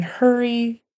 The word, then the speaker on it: hurry: And hurry sounded a little bit like Harry. Got to get that E-R sound here, even though it's spelled with a U-R.